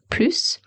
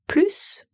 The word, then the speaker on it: plus: P-L-U-S, plus, parce que ce mot, en fait, a trois prononciations. On peut dire plus, on peut dire plus ou on peut dire plus. Et ça, ça dépend du contexte. Et ça dépend des mots qu'il y a après.